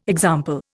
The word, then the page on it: example: Indian